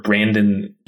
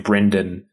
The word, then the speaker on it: Brandon: Uh, fun fact: my brother pronounces my name Brendan, and to this day re- refuses to pronounce it Brandon.